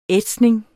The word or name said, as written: ætsning